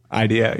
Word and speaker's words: IDEO: Is it IDEO? – IDEO, yeah, exactly.